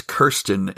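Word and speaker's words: Kyrsten: Sinema won and it's awesome and we're very happy to have you know, such a great future senator or senator elect as Kristen Sinema. I'm happy about it. I'm happy to be wrong. But. And I think I think I pronounced it wrong. I think it's Kirsten.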